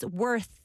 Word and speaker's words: worth: this one, the pronunciation's a little hard. It's worth with a th at the end.